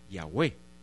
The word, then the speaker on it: Yahweh: Después lo pasamos cómo se pronunciaba esta forma, y sabemos que se pronuncia como Yahweh.